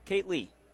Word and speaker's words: Kate Lee: It is Kate Lee. Kate Lee, so like Kaylee with the T.